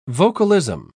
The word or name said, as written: vocalism